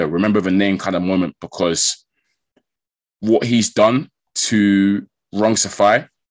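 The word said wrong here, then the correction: Rungvisai: in 8 rounds, I'm probably butchering that name, innit? How do you say it? – Rung Vasai. – What he did to Rung Vasai in 8 rounds, special stuff.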